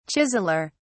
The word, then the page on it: chiseller: British English